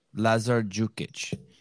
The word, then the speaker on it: Lazar Đukić: Lazar Dukić. – And, yes. And that D is pronounced like, I always pronounce it like a J, Dukić. – Yes. – Is that how you pronounce it? – Yes.